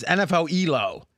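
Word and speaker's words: nfelo: We have Neflo come. You know what's funny? I heard someone pronounce that. I've been pronouncing it. It's NFL Elo. But for some reason, yeah, it makes more sense.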